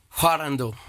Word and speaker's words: Hwarangdo: Say it right for me again. Hwarangdo, is that right? – Hwarangdo. – Hwarangdo. – That's right.